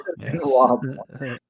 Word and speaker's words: Benoit Blanc: Benoit Blanc.